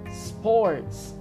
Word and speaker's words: sports: Olha que em inglês, perceba que a gente não pronuncia nada antes do S. A palavra começa com S, então a gente fala sports.